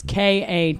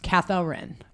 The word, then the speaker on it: Katharyn: It's Katharyn. Yeah. So it's technically, and we'll never do this, pronounced Katharyn. – Katharyn. – Yeah, but I'm not playing that game.